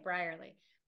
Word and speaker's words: Brierley: Welcome to our group discussion on my book choice, A Long Way Home by Saru Brerley. Is it Brerley? – Let me see the name. – I thought it was Brierley, but that's how I would pronounce it. Yeah. – Okay, Brierley.